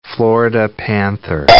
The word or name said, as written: Florida panther